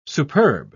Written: superb

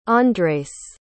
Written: Andres

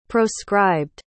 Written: proscribed

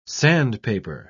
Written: sandpaper

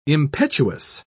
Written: impetuous

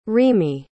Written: remee

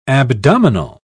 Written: abdominal

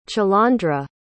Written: chalondra

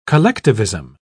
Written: collectivism

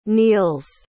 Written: Niels

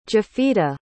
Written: jafita

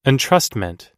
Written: entrustment

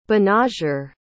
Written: banagher

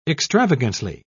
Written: extravagantly

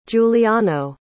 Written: juliano

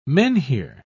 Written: menhir

